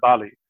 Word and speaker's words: Mbale: And we took our ride with our taxi driver to a town called Mbali, M-B-A-L-E. They pronounce it Bali.